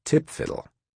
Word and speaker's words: TPFDL: Back then, this complaint about messing with the painstakingly developed TPFDL, an awkward acronym that military types pronounce tip-fiddle,